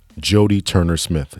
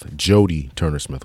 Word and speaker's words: Jodie Turner Smith: I wanted to talk just quickly about, we're going to be saying the woman's name wrong. She pronounced it Judy. The name of the actress in today's movie is actually Jodie Turner Smith. Jodie Turner Smith. A couple of times we said Judy.